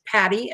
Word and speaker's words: Patty: It is Patty, and my name is spelled with a Y.